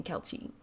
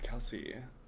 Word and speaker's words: Kelce: Yeah, I've been walking around saying Kelce. – No, it's Kelce. – Okay. – Like a girl that I went to high school with. – Yeah. – Kelce.